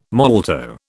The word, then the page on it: molto: British English